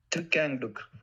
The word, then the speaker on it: Thich Quang Duc: I'm going to pronounce it Thich Quang Duc. – I will insert the pronunciation right now. – Tukangduk.